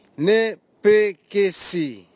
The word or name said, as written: nepekesi